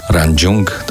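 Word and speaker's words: Rangjung: Pisze książki Chiny, kuchnia, tajemnice medycyny, trzeci karmapa Ranjung, dobrze wymawiam? Tak. Dorje, świadomość zwyczajna, świadomość przebudzona, Tybet, Tybet legenda i rzeczywistość.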